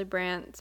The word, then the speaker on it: Brandt: B, my name is Ashley Brandt, spelled with a silent D.